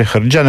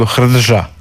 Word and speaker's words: hrđa: I onda ono možeš primijetiti u sitnici, kako on ne kaže hrđa, nego hrdža. – Je. – Jer zapravo izgovara slovo po slovo.